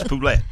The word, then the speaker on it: Poulet: It's spelled like roulette with a P. I would say Poulet.